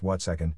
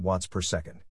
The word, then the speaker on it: watt second: That's pronounced watt second, not watts per second.